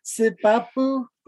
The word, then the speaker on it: Sipapu: Yeah, I couldn't say that word. – Sipapu. – Sipapu. Sipapu. Sipapu. – Sipapu. So you guys pronounce it how you want.